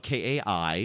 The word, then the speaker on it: Kai: It's spelled K A I. Which is Kai. Anyway, so this girl's name is Kayla, K-A-Y-L-A, and she has, I think it's like almost like a janitorial job or something.